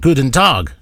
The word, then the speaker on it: guten Tag: He sent an email in, and he goes, "You sounded pretty good saying guten Tag." Guten Tag, yeah, which is, he says it's good morning, and I pronounce it correctly.